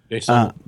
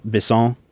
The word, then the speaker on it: Besson: And the tale that's included on the DVD talks about how, I think it's Besson is how you say his name, or Benson. I'll just call him Benson. – Besson. – Besson. – Luc Besson. Besson.